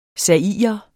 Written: zairer

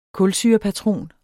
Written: kulsyrepatron

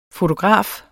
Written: FOTOGRAFER

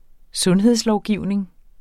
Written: sundhedslovgivning

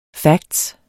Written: fact

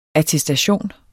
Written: ATTESTATION

